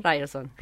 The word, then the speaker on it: Ryerson: Ryerson. – Ryerson de Toronto, exactamente.